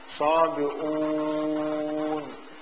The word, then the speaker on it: صابئون: كذلك الصابئون، الصابئون في الرسم القرآني الهمزة على كرسي ليست على الواو، بينما هي في التلفظ تقرأ همزة كرسي وها الواو، يعني ليس همزة مضمومة وواو كما هو في الرسم، في التلفظ صابئون، ولو كانت تلفظ كما هي مرسومة لصارت عندنا 3 ضمات صابئون، بينما التي تلفظ صابئون،